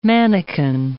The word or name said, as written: mannequin